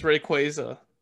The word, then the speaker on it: Rayquaza: I've been pronouncing his name wrong? – Yep. – What is it? – It's Rayquaza.